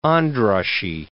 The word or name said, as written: Andrassy